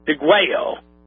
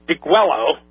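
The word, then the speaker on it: Degüello: It was an anonymously written document entitled, and I'm going to give you the spelling because it's a Spanish word, D-E-G-U-E-L-L-O. Now, my initial gut feeling from what Spanish I do know is that would be pronounced Deguello. But for simplification purposes, we're just going to call it Deguello because that's the phonetic pronunciation of it, folks. D-E-G-U-E-L-L-O.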